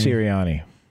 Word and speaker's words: Sirianni: How you say it? Sirianni? – Sirianni.